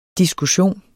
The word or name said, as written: diskussion